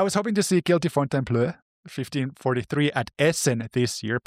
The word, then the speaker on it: Fontainebleau: I was really hoping to see Guilty Fontainebleau. Am I pronouncing this right? Which I learned this year that you speak French. – Me? But very little. – Moi? – Fontainebleau.